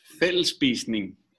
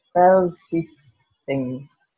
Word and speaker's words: Fællesspisning: So you're saying that that's the most popular part of what you do at Absalon, the evening meal, which, I'm sorry, I can't pronounce it in Danish. – Fællesspisning. – Fællesspisning. – Perfect. Well done.